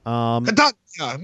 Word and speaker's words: Catania: The decision came after a 69-year-old man was arrested and charged with masturbating in front of students at the University of Catania, I think is how you say it. – Catania, meatball.